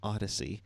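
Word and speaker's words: Odysee: Odyssey. That's how you say it. Odyssey.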